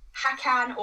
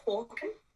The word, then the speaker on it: Håkan: Like every time I speak to someone about this film, everyone's always like, yeah, his name's Hakan or Harkan, but Apparently, according to the Swedish pronunciation, it's Håkan. – Håkan. – Which I was like, oh, yeah, Håkan. I was like, oh, okay, like a hawk.